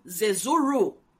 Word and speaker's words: Zezuru: So did you say Zezulu or Zezuru? – With R. – Oh, so Zezuru.